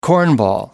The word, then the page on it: cornball: American English